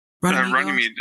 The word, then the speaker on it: Runnymede: Runnymede bookstore.